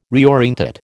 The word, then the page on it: reorientate: American English